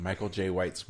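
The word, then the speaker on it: Michael Jai White: I'm on Michael Chai. Jesus, why can't I say his name? Michael J. White's web IMDb page.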